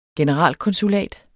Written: generalkonsulat

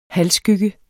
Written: halvskygge

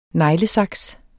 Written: neglesaks

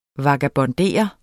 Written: vagabondere

